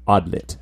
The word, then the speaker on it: Adlet: pronounced, it's spelled A-D-L-E-T, mm-hmm. but it looks like it's pronounced Adlit, actually.